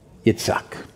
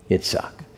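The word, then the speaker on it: Yitzhak (יצחק): Anybody know how to pronounce Isaac in Hebrew? Yitzhak. Yitzhak.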